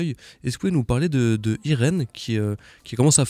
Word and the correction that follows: Irénée: Ouais, du coup, en fait, ça se prononce Irénée. – Irénée, pardon. Ouais, c'est pas grave.